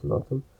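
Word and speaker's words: Laughton: It's pronounced Lawton in Essex. Yeah.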